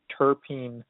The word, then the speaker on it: terpene: terpene vodka. Is that am I pronouncing that right? – Yes, you are.